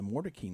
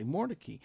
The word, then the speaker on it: Mordecai: And how, you know, I was just interested that driving up Wake Forest Road and you see these signs that say Mordecai, but it's Mordecai. You mean the original pronunciation is Mordecai. – Mm-hmm. Mm-hmm. – So if you're not from Raleigh, you don't realize that the pronunciation changed.